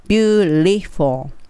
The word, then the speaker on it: beautiful: はい、その次に That's beautiful。はい、この場合も beautiful の部っていう場合は唇入れてしまって beauty t なんですが beautiful なんですけども t にせずにここも l の発音で言っちゃいます beautiful beautiful っていいです for の時は下の唇に歯をちょっと当てて for ですね beautiful はいその次に that's wonderful はい。